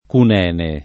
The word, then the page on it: Cunene: Italian